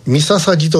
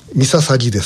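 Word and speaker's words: 御陵: ミササギと読みます。ミササギです。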